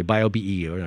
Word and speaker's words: BioBE: How do you pronounce that? – We call it BioBE for short because it's quite a few names in that title. – Okay, BioBE. I just wanted to see what the shorthand was.